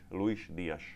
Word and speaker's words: Luis Díaz: On aj myslím, že rozpráva tak, že ako spra- správna výslovnosť si myslím, že jeho mena je Luis, Luis Díaz.